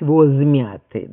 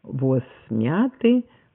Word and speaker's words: воз: Вот я хочу обратить внимание на то, что в соответствии с нормой здесь перед сонантами возможен только глухой согласный. Неправильно говорить «воз мяты», да, или «воз веток». Только «воз мяты», «воз веток». И даже если дальше идет гласный в сочетании «воз огромный», да, то тоже глухой согласный.